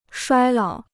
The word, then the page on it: 衰老: shuāi lǎo